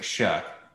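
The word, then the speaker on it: Shough: There's a cut at the end. It's like shug. – That's a shug, I think it's good.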